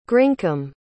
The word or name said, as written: grincome